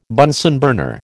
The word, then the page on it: Bunsen burner: American English